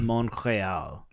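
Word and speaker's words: Montréal: You don't pronounce the T? – The French way of saying Montreal is Montreal.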